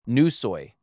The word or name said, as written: Nusoi